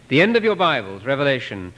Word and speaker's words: Revelation: And maybe I should mention that it shouldn't be pronounced Revelations. They say that at Fuller Seminary, if one of the students calls it the Book of Revelations, his grade is lowered. That's a popular mispronunciation. It's singular.